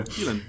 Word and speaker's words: Gillen: Gillen.